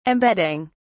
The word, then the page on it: embedding: British English